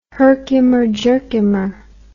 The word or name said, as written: Herkimer Jerkimer